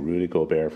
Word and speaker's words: Gobert: That's when Rudy Gobert from France, I'm not saying anything that he was from France, I'm just pronouncing his name correctly. Rudy Gobert from France, NBA player, tested positive for the virus.